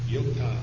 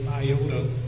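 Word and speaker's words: Yota (ἰῶτα): Yota. You'll be tempted to say iota, but it's not iota for many reasons that I've mentioned.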